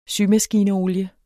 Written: symaskineolie

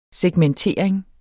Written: segmentering

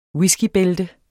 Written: whiskybælte

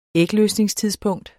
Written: ægløsningstidspunkt

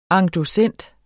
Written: endossent

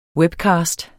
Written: webcast

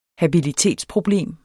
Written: habilitetsproblem

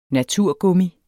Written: naturgummi

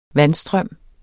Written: vandstrøm

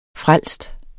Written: frelst

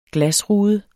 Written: glasrude

